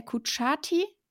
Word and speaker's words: Kutschaty: heißt der Kutschaty? – Ich glaube ja, also so habe ich es bisher ausgesprochen gehört.